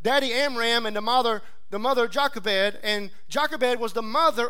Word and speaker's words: Jochebed: Some people pronounce it in different ways, but I've heard it pronounced Jochebed.